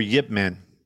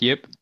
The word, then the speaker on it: Ip Man: She was in the IP Man. – Or Yip Man. – Yeah, oh, that's how it's pronounced? Yip? – Yeah.